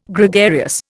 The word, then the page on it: Gregarious: Indian